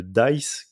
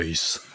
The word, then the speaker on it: DICE: le DICE, qui est donc à sa troisième édition faut le prononcer un peu plus avec plus de voix, c'est le dice.